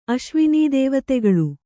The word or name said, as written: ಅಶ್ವಿನೀದೇವತೆಗಳು